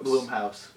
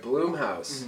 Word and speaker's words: Blumhouse: Is it Blumhouse or Bloomhouse? – Bloomhouse. – All right, Bloomhouse mm-hmm.